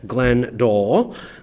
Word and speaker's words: Glenn Doell: And we're happy to be joined by a special guest, Glenn Doell.